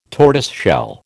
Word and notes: tortoiseshell: American English

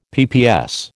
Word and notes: PPS: American English

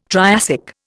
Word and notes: Triassic: Indian

